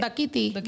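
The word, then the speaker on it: Dákiti: On the Dakiti remix, Dakiti or Dakati? – I have no idea how to say it, but... – Dakiti. – Wait, we said... – Dakiti. – Yeah, Dakiti.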